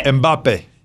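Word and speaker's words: Mbappé: I'll put in a call for Mbappe. – Mbappe. He's busy beating Messi. – No extra syllable.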